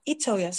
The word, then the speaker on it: Itoya: Yeah, that's my full name, but like I made a shirt like just to be like a stage name, Love Itoya. So it's not that. – Love Itoya. Yes. Love Itoya. So I say it like E, even though it's I.